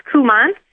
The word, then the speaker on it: Kuman: It's hard to pronounce because it's Dutch. It's Kuman.